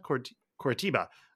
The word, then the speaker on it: Coritiba: Um, and now Marcy, Marcy Moreno, he was loaned to uh, Corabita. Is that how you pronounce that? Coritiba uh, last year um, in Brazil.